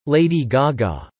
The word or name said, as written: Lady Gaga